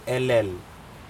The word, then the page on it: elel: Aneityum